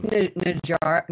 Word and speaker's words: Najarra: I'm um, Najarra, right? Is how you say her name. – It's um, Najarra, Najarra. – Yeah, Najarra.